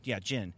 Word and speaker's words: Jin: Jen, she could have survived. Or, am I saying her name right? – Yeah. – Yeah. Jin. She could have survived and just left and just been like, this is over.